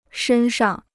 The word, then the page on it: 身上: shēn shang